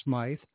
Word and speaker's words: Smyth: She changed the spelling to S-M-Y-T-H and the pronunciation to Smyth instead of Smith, so she wasn't confused with Patti Smyth, the punk rocker.